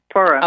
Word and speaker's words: Purim: Speaking of history, the Jewish holiday of Purim. Did I pronounce that correct? Purim? – Yes. Mm-hmm. Purim.